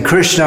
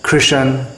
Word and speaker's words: Krishna: Just like in Hindi, instead of saying Rama, Ram, instead of saying Arjuna, Arjun, and instead of saying Krishna, often they say Krishan, Kishan.